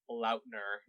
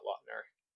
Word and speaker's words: Lautner: And by the way, fun fact, it's pronounced Lautner, not Lautner.